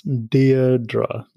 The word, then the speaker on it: Deirdre: So your name is pronounced as Deirdre, D-E-I-R-D-R-E.